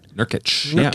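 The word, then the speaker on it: Nurkić: Nurkic.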